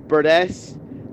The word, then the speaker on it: Burdess: Hi lads, so my name is George Burdess, and I get associated with the snapper quite a lot with my second surname, despite it being spelt differently. – Yeah. – Mine is spelt B-U-R-D-E-S-S, and Burgess is obviously with the G.